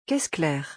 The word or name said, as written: caisse claire